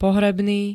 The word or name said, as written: pohrebný